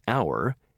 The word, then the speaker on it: hour: In a few words, like honest and hour, the H is never pronounced.